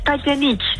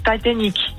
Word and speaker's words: Titanic: Nou, het is Titanic. Het is zeg maar hoe Celine het op zijn Engels met haar Franse accent zou zeggen. Dus Titanic.